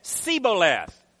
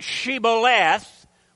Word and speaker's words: shibboleth: Then they would say to him, say now, shibboleth. But he said, sibboleth, for he could not pronounce it correctly.